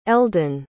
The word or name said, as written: eldan